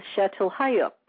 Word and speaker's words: Çatalhöyük: And major focal civilizations is Çatalhöyük, correct? Am I pronouncing that correctly? – Yeah, yes, you are.